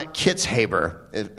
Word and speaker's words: Kitzhaber: John Kitzhaber, is that how you say his name? Yeah.